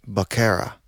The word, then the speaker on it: vaquera: And this is spelled V-A-Q-U-E-R-A. – V. – Right. Huh. But in Spanish, that V be sounding like a B.